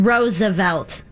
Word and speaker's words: Roosevelt: The president pronounces his name Roosevelt in three syllables.